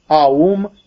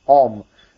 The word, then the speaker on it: Aum: And then, actually, when you pronounce it colloquially, you can as well say Om. Remember, if you say Om, it is conceptually wrong for your meditation, because you have destroyed the triadic structure.